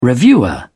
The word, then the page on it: reviewer: British English